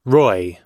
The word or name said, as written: roy